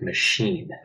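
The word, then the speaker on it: Mašín: So his name is actually Dominic Machine. It's one of those funny Czech pronunciations.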